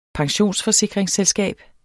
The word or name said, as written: pensionsforsikringsselskab